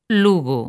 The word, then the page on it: Lugo: galega